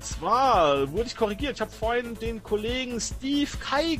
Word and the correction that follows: Steve Kay: genannt. Er spricht sich aber wohl Steve Kay. – Oh, gut, alles klar. Gominasai. – Er sagt hier ganz klar, da spricht man Englisch aus. Dann werde ich das einfach mal Englisch aussprechen.